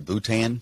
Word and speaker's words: Bhutan: Probably when he went over to, I don't know if I pronounce this right, Bhutan.